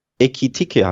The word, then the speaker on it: Ekitike: Ekitike heißt er, glaube ich, oder? Etikite, du hast ihn anders ausgesprochen.